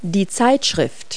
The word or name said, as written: magazine